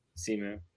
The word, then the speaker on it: Simu: She said that Samuel Liu, I think that's how you say his name. – Simu.